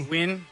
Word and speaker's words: Nguyen: Nguyen.